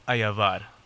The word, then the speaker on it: Ayabar: Ayabar.